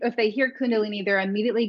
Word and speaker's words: Kundalini: But the question I have for you is, explain Kundalini to, did I say that right? Kundalini or Kundalini? – Kundalini.